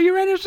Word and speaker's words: Uranus: I think all of them should go to Uranus. – Now, you can't make no joke about the rocket ship, but you can say that. – I thought Uranus is the planet.